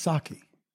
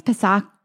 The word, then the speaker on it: Psaki: I think her name is pronounced Saki. – No, I think it's Psaki.